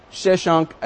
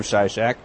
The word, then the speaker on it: Shishak: It says, did Pharaoh Shishak attack Jerusalem? And it's about this time when Shishak, as it says in the Bible, that's the way the Hebrews pronounced his name, came and attacked and captured the fortified cities of Israel and went right up to Jerusalem.